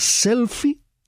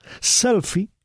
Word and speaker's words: selfie: Selfie, en inglés, se escribe con una E al final. Selfie, aunque esa E no se pronuncie. Se dice selfie.